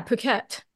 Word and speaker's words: Phuket: So, I know you were on vacation in Phuket. Is that how you pronounce it? – Yeah, Phuket.